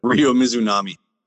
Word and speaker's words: Mizunami: Ryo Mizunami.